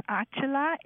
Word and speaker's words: Achala: Okay, my name is Achala, A-C-H-A-L-A. – C-H-A-C-H-A-L-A. – I got that. – Last name is Chatterjee, C-H-A-T-T-E-R-G-A-E-E.